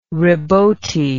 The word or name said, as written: rebote